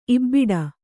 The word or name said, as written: ಇಬಡಿ